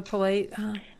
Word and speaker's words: Pleiadians: I was reading that how do you say them again? The Pleiadians. – Palladians.